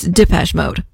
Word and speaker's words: Depeche Mode: Lead singer David Gahan said in a 1984 interview that they thought they knew how to pronounce it until they went to France and learned, no, it's Depeche Mode.